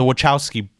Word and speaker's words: Wachowski: The Wachowski brother. Okay, so it's not like a K, it's a Wachowski.